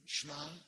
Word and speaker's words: Shema: Now, we think Jesus often was teaching in Aramaic, and it's the same word, same pronunciation in Aramaic, which is sort of like street Hebrew. It's not the same as Arabic, not the same, but it's sort of like street Hebrew, Shema.